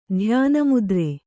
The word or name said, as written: ಧ್ಯಾನಮುದ್ರೆ